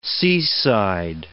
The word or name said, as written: seaside